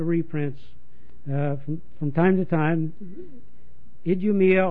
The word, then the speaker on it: Idumea: The Romans called him Idumea. They pronounced that Idumea.